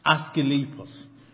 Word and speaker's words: Asclepios: Would you like to tell us how it's pronounced? – Asclepios.